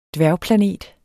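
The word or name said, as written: dværgplanet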